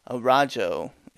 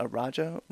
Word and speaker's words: Araujo: Alexis Davis versus Vivian Araujo. Is it Araujo? Araujo. – Araujo? – No. She's Brazilian. Do not pronounce it that way. I think it's Araujo, if I remember right.